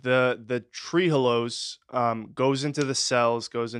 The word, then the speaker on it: trehalose: Is that how you pronounce it? Trehalose? – Trehalose. – Trehalose sounds good.